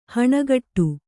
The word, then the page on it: ಹಣೆಕಟ್ಟು: haṇekaṭṭu